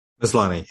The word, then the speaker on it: Maslany: I think you would pronounce that Tatiana Maslany. – Maslany, yeah, I think.